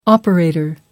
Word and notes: operator: American English

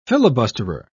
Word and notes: filibusterer: British English

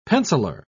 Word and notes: penciler: British English

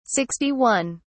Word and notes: 61: British English

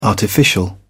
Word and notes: artificial: British English

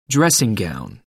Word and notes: dressing gown: American English

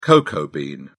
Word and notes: cocoa bean: British English

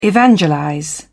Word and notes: evangelize: British English

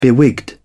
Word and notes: bewigged: British English

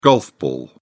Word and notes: golf ball: British English

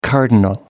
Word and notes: cardinal: American English

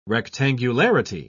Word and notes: rectangularity: British English

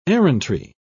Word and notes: errantry: British English

